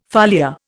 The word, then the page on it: foliar: Indian